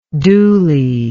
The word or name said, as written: dhooly